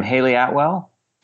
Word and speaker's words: Hayley Atwell: Hayley Atwell? – Yes.